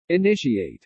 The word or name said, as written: initiate